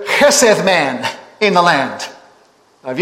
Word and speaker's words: chesed: Looks like chesed, the way it's spelt sometimes in a lot of commentaries, but that's the pronunciation in the Hebrew, chesed. And so what he's saying here, this word chesed that means love, kindness, pity, compassion, mercy, grace, loyalty, loving kindness is a word that tries to bring some of that together.